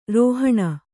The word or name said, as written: ರೌಹಿಣ